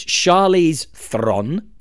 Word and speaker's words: Charlize Theron: So it's Charlize Theron, for that is how it's pronounced.